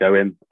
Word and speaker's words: Owain: For those curious as how to say my name, it's pronounced like Owen, even there's a bit of Welsh in there.